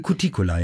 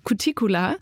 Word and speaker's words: Cuticula: Ich weiß nicht, ob ich es richtig ausspreche. Cuticula? Cuticula? Cuticula, ja. Cuticula. Genau. Äh, Cuticula ist, glaube ich, bei einer Pflanze die äußerste Haut.